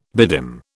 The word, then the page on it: bedim: British English